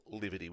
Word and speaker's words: Liviti: Now, Liviti is spelled L-I-V-I-T-I, Liviti.